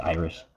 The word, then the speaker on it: Aerith: I mean, like the opening movie, if you ignore Iris, and that's how you pronounce her name if you look at the Japanese spelling, it's Iris, not Aerith or Aeris.